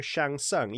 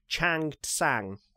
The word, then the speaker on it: Shang Tsung: But then, to make it worse, it's the first thing I've written here, like, somewhere along the line, they've decided that he's no longer Shang Tsung either. He's now Chang Tsang, apparently, is the way it's pronounced in the opening.